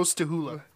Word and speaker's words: Stachula: It's spelled Stachula, but they call him Joe Stahula.